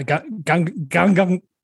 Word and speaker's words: Gangnam: Did I say that right? – Yeah, Gangnam Style.